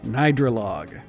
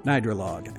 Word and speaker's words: Gnidrolog: And I believe the artist is pronounced Nidralog. Now, I'm not exactly sure. I think I'm pretty close on that. It's spelled G-N-I-D-R-O-L-O-G.